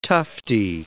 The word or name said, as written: tufty